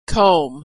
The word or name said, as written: comb